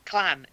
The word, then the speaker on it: Wu-Tang Clan: And has listened to too much Wu-Tang Clang.